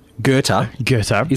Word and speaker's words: Goethe: Goethe. Is pronounced Goethe. – It's pronounced Goethe. So I was looking at it. Now, for people who can't see the word that's in front of me, it's spelled G-O-E-T-H-E. – Correct. Yeah. Mm. Goethe. – Would you have pronounced that Goethe? – I would have looked a couple of times and think, I know that the T-H is not T-H, yes.